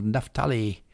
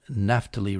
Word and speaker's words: Naphtali: Naphtali, and from the sons of Zebulun. I think I pronounced Naphtali wrong. It's Naphtali or Naphtali, your choice, but it's not Naphtali.